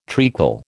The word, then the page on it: treacle: American English